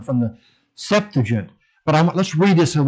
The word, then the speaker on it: Septuagint: And of course, the Septuagint, I believe that's how it's pronounced. I believe the accent is over the first E, Septuagint, was written in 300 to 200 BC by 70 to 72 Jewish scholars.